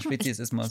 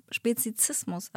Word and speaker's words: Speziesismus: Ist es richtig ausgesprochen? – Ja, Speziesismus. – Ich dachte, es heißt die ganze Zeit Speziesismus, aber es heißt Speziesismus.